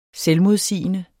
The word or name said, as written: selvmodsigende